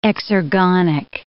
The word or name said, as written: exergonic